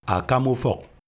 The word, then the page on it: Akama ufawk: LHHHL